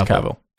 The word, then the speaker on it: Cavill: Yeah, and the IMDb, it says, like, his last name is pronounced like as if you were to say travel. – Oh, okay. So it's Cavill.